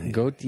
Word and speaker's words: Goethe: Goethe.